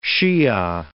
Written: Shia

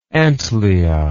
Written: antlia

